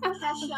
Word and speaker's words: Kasha: I know, I get it all. I get Kasha, I get Keisha.